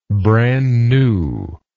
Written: brand newness

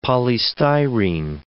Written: polystyrene

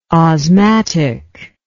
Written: osmatic

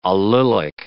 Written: allylic

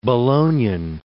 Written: Bolognan

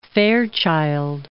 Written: Fairchild

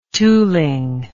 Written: tooling

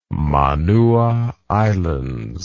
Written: Manua Islands

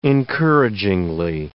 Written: encouragingly

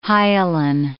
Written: hyalin

